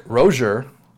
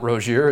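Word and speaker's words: Rosier: Malik Rozier, as we learned that his name is pronounced, and not Rozier, as we've all been saying.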